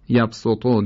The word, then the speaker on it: یبسطو: کسی که مد متصل و منفصل رو به توسط میخواند، یعنی داره طریق شاطبیه میخونه، اینجا نباید یبسطو و بسطه رو به صاد بخواند. هرچند جایزه، اما از طریق دیگر، از طریق شاطبیه فقط سین. یبسطو در بقره و بسطه در اعراف فقط به سین تلفظ میشه.